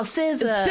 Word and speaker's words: SZA: SZA, how you say it? – Oh, SZA.